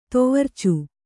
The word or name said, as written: ತೊವರ್ಚು